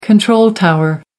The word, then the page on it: control tower: American English